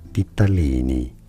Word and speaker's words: ditalini: It is said as ditalini.